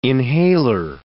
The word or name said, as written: inhaler